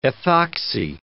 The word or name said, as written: ethoxy